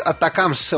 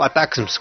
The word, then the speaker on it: ATACMS: Se trata de los misiles ATACMS, o ATACMS, como se puede pronunciar de las dos maneras,